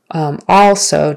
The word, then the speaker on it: also: Also, so it's ah, the A is ah, as in father, tall. So, also, don't say also with a short E. It's not also, it's also.